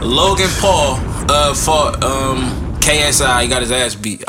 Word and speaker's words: KSI: How you think you say that shit? – Si. Kasai. – No, it's KSI. But anyway, literally KSI.